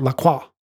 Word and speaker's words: La Croix: natural flavors in beverages such as La Croix, sparkling water. You have to say it like that, La Croix.